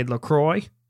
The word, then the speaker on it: LaCroix: And now, last week, we couldn't figure out how to say LaCroix.